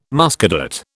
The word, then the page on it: Muscadet: British English